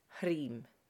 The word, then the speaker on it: Hrim: So the mantra I've chosen for us to practice today is Hrim. You'll see it written in English letters H-R-I-M, pronounced Hrim.